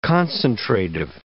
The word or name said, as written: concentrative